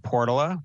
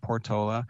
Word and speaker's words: Portola: So Bayview, Hunters Point, the Portola, which is how locals pronounce it, not Portola,